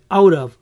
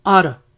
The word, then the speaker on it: out of: Como ya pueden haber notado, la T de out of en la mayoría de veces se pronuncia como una R. Out of, out of. En otros casos, por la velocidad, puede que suene simplemente ara, ara.